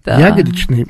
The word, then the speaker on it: ягодичный: да, о чем мы говорим сейчас с Екатериной, о том, что о правилах русского языка и в слове ягодичный мост мы делаем ударение на первый слог.